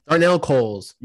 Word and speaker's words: Darnell Coles: They hired, is it Darnell? – Darnell Coles. – Yeah, okay. I just wanted to make sure I wasn't mispronouncing his name, but he was the former Diamondbacks hitting coach.